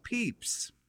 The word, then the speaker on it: Pepys: This is in part because Pepys, pronounced Pepys,